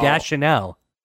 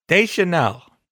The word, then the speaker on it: Deschanel: I just want to point out, you're still saying her name wrong. Deschanel. – Deschanel. – Nope.